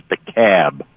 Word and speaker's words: DeKalb: Now, anywhere else in the world, it's DeKalb, except in... – I'm from Illinois, and we pronounce it DeKalb.